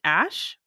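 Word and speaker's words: Æ: And apparently the AE is an ash. It's pronounced ash for some reason.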